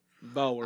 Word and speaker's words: Boer: B-O-E-R. Boer.